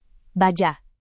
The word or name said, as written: baja